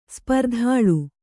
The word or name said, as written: ಸ್ಪರ್ಧಾಳು